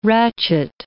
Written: ratchet

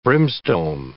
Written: brimstone

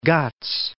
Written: Eastern Ghats

